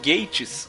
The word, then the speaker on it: Gatiss: Que, segundo a nossa ouvinte, né, a Joaninha Trekker, o nome dele se pronuncia Mark Gatiss. – Gatiss, é verdade.